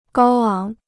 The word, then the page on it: 高昂: gāo áng